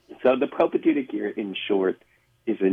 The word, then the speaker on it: propaedeutic: So new seminarians this year are in what is now known as the propedeutic year. Did I pronounce that correctly? Can you explain what this is? – That's right. And if you spell it correctly, we'll give you a gold star. – P-R-O-P-A-E-D-E-U-T-I-C.